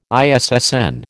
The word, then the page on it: ISSN: American English